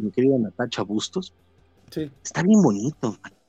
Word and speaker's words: Natacha: Y pues no, no, no termina de jalar muy bien, pero el arte de Natacha, Natacha, y, no estoy pronunciando mal la, la, así se llama Natacha.